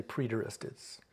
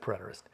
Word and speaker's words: preterist: Some people say preterist. I pronounce it preterist.